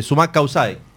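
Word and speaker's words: Sumak Kawsay: Mira, lo voy a decir con naturalidad, los vinos Sumac Kaisai. ¿Lo dije bien? – Sí.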